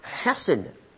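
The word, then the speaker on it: chesed (חסד): Now, the Old Testament word for this kind of love is a word pronounced chesed.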